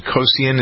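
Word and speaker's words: Kocian: Laurie Hernandez, Madison, is it Kosian? Is that how you pronounce her name? I think so. – That sounds right.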